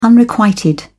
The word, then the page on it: unrequited: British English